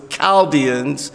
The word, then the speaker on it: Chaldeans: And you would think it say Chaldeans, but it's Chaldeans. That's how you pronounce it.